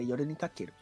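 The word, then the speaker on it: Yoru ni Kakeru (夜に駆ける): Eu falei errado a música, mas é Yoru ni Kakeru.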